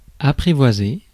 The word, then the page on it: apprivoiser: [a.pʁi.vwa.ze]; France